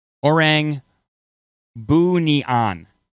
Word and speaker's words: Orang Bunian: So it is pronounced the Orang Bunian, Bunian, Orang Bunian.